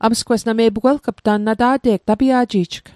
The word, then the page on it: tapia'ji'jg: Mi'gmaq